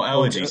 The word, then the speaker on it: Elegy: How do you pronounce that second word? Hillbilly Elegy? – Allergy, right? – Elegy? Elegy? – Oh, Elegy. Sorry. No, Elegy.